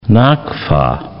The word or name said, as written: nakfa